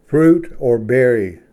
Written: ᎤᏓᏔᏅᎯ

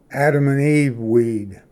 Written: ᎠᏓᏫ

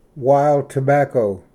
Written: ᏦᎳ ᎠᎦᏴᎵ

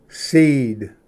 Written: ᎤᎧᏔ

